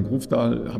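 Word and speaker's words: Grøvdal: Es wird auch ein sehr spannendes Debüt geben, nämlich mit von einer norwegischen Athletin, nämlich Caroline, ich glaube, Grofdal spricht man so aus, richtig? – Grofdal, ja.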